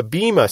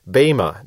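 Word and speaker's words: bema (βῆμα): Now, judgment seat in Greek is the word bema, and that is spelled B-E-M-A. Now, some people in Christian circles talk about the bema seat, and they're talking about this same thing, although they're mispronouncing it. In Greek, it would be bema, not bema.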